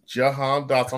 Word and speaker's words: Jahan Dotson: He's going to have Jahan Dotson.